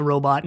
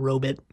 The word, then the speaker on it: robot: it's pronounced robot, not robit.